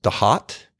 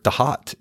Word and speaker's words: Thoth: And, you know, I was recently came across Damien Eccles' work, and he really claims that Thoth should be pronounced the hot.